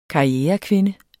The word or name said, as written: karrierekvinde